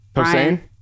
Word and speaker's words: Posehn: And I remember going to a comedy show with Brian Posner, which you probably know. – Posehn? – Posehn. I'm getting his name wrong.